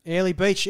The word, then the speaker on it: Airlie Beach: Did I say that right? – Airlie Beach, yeah.